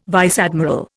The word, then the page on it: vice admiral: Indian